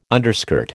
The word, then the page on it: underskirt: American English